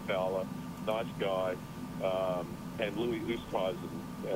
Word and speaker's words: Oosthuizen: Oosthuizen said once in an interview that it's supposed to be pronounced Westhuizen.